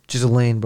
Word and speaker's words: Ghislaine: Ghislaine, bro. Don't ever say her name like that. – Whatever her. What's that white woman's name? – Ghislaine. – The one that's holding the list of all those names. – That's probably how it's actually pronounced, but just don't say that shit.